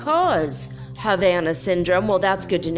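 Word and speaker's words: Habana: Havana Syndrome, by the way. You don't even pronounce the H when it's Spanish, but whatever.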